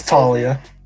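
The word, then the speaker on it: Taulia: It's Talia. Just Talia. – Talia? – His name is pronounced Talia Tagovailoa.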